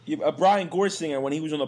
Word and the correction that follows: Gorsegner: It's not Brian Gorsinger. It's Brian Gorsegner, I think.